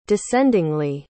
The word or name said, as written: descendingly